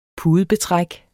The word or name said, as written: pudebetræk